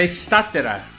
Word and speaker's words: Statera: Estatera ou Estatera? Tem alguma forma correta de pronunciar? Eu estou pronunciando errado? É Estatera.